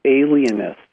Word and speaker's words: alienist: I didn't mispronounce that, alienists. – Wow.